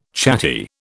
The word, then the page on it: chatty: British English